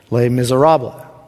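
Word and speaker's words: Les Misérables: And we watched Les Miserables. I didn't say S at the end because Dr. Clark will jump on me if I mispronounce it tonight.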